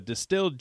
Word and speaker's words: DSTLD: So Distilled jeans, I love what they're doing. There's no middleman. So this is basically it's DSTLD, and it's pronounced distilled.